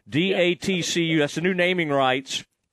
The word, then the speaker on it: DATCU: It's D-A-T-C-U, which is weird because TCU.